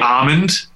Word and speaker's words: almond: Well, I always said almond, but it was Seth that pointed out to me that you actually are not supposed to pronounce the first L. – So it's supposed to be almond? – It's supposed to be almond, which is insane.